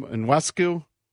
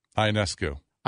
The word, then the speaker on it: Ionescu: Inescu? – Ionescu.